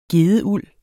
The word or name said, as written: gedeuld